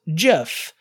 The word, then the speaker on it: GIF: Now, Steve Wilhite pronounces it GIF, and that therefore he's wrong, which I'm joking. He's not really wrong. In fact, various dictionaries say that both pronunciations are acceptable. But, I mean, come on, the G stands for graphic, not giraffic.